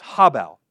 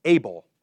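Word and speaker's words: hebel: One more thing, the word for breath here is habel, habel, which if we were to pronounce it the way we pronounce things in English, we would pronounce it as able, able, able of Abel's, all is Abel.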